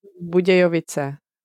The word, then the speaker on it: Budějovice: Budějovice.